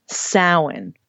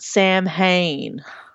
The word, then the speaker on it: Samhain: And lastly, Samhain. For you True Blood fans out there, not Samhain. Okay? That would drive me bananas when I would hear that. I'm like, Samhain.